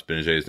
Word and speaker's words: Gbinije: And depending on whether they hold on to Darren Hilliard or Michael Gbinije, uh, did I pronounce Gbinije's name right? – Yes, you did.